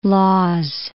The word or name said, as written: Lawes